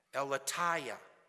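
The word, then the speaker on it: aletheia: That word truth, according to the Strong's Concordance, is pronounced eleteia.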